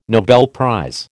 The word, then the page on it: Nobel prize: American English